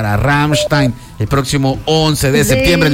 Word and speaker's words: Rammstein: Me llega, no, mire, se dice Rammstein. A ver, repita. Rammstein.